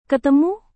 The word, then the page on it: ketemu: indonesian